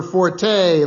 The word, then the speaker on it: forte: Richard, I believe it is correctly pronounced Fort.